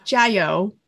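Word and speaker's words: Jayo (加油): How do I say it again? Jayo. – Yeah, Jayo. – Jayo.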